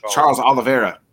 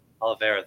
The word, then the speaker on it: Charles Oliveira: I mean, Olivier, I might have pronounced his last name wrong. – Charles Oliveira. – Oliveira, thank you.